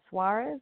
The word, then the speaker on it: Suarez: Lillian Suarez, last name spelled S-U-A-R-E-Z.